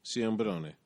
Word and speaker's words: Ciambrone: How do you say his last name? – Ciambarone. – Sim- another Italian. I can't say the Italian name. Ciambrone.